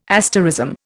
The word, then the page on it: asterism: Indian